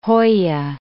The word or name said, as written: hoya